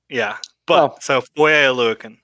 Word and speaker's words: Oluokun: The last name, I even butchered it earlier today, but it's Oluokun. – Oluokun. – Oluokun.